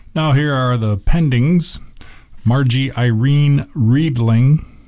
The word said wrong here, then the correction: Readinger: Reedinger, age 76, and that's spelled Readinger.